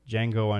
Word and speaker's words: Django: Django Unchained. I believe the D is unpronounced based on what I hear from other stuff.